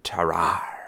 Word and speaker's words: Tarrare: So today we're talking about a French showman and soldier named Tarrare. – Yeah, you said it right.